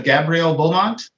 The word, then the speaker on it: Gabrielle Beaumont: That was the birth date of Gabrielle Beaumont. – Okay. – I hope I pronounced her name correctly. – Yes.